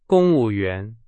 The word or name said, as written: 公务员